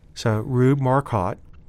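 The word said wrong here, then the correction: Marquard: It's actually pronounced Marquat. – Marquat, okay.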